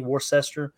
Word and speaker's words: Worcester: I mean, it was Albany, and then Worcester, I guess is how you say, Worcester. Not the sauce either there, you knuckleheads.